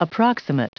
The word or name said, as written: approximate